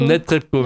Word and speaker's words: Netrebko: D'ailleurs, avant que j'oublie, le rôle de Violetta vous a été présenté par la soprano Anna Netrebko. D'ailleurs, j'arrive pas à le dire à chaque fois. – Anna Netrebko. – Netrebko, merci.